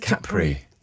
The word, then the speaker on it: Capri: I think it's Capri. – I think the Italians say Capri. – I suppose Capri. – With the accent on the A.